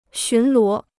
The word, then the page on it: 巡逻: xún luó